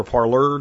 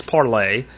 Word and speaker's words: Parler: The one question I'm getting left and right from people, I'm getting it on Parlay or Parler, depending on how you want to say it or how you want to pronounce it, but it's Parlay.